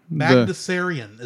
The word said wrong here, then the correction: Bagdasarian: is my best estimation. – Magnusarian? – Bagdasarian. – Thank you.